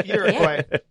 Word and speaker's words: Uruguay: Uruguay. Wow. Is that how you say it? – Yeah, why not? Yeah, that was it. – Yeah. Wow. Uruguay.